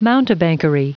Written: mountebankery

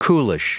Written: coolish

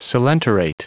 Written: coelenterate

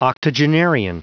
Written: octogenarian

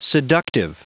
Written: seductive

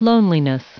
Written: loneliness

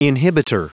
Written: inhibitor

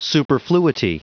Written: superfluity

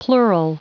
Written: plural

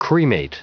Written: cremate

